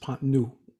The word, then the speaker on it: Pont Neuf: I believe it's pronounced pont neuf.